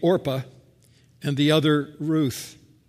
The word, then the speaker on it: Orpah: It's going to be hard for some of us because people couldn't pronounce the name, and so they began to call her Oprah instead of Orpah, which is what the birth certificate originally said. So every time I say Orpah, I think I'm mispronouncing the name. But it's Oprah's name who's wrong, not this woman's name who's wrong.